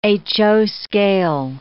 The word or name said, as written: HO scale